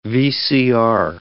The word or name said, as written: VCR